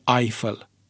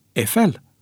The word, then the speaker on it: Eiffel: Așadar, pronunția nu poate fi turnul Eiffel, ci Eiffel, cu accentul pe cea de-a doua silabă, pentru că autorul ei este francez, nu german.